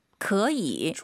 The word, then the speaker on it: 可以: 可以. – 좋아요. 삼성이 두 개니까 앞에 可, 그 다음 반삼성, 可以.